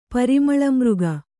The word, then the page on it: ಪರಿಮಳಮೃಗ: Kannada